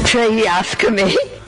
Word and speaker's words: tracheostomy: She has a trachostomy. What do you say? How do you say this? Trachostomy? – Tracheostomy.